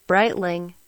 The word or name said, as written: Breitling